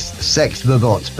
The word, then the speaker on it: S3XVERBOT: That was Sex Verbot, which actually spelled S3XVERBOT. You know what these young people are? I think they're cool, don't they? But it's Sex Verbot, but the name of the band is, well, the band is spelled S3 rather than E.